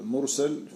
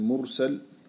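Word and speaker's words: mursal (مُرْسَل): Der er forskel mellem mursal og mursil. Den første der med fath sin, stregen er over bogstaven. Den anden med kasr sin, stregen er under bogstaven.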